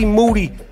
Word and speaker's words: Moody: Thursday, July 26th in Toronto, me and G Moody. Last name rhymes with duty.